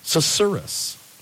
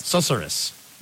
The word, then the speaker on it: susurrus: Susurrus. – Susurrus? – Susurrus. Okay, that's how you pronounce it. S-U-S-U-R-R-U-S. There's a lot of U's and a lot of S's and a lot of R's. Susurrus.